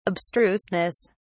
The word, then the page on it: abstruseness: British English